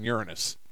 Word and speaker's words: Uranus: That would have to be Neptune and Uranus. – Oh, you got it. And, of course, you went for the proper pronunciation as to not to get the entire crowd into hysteric laughter.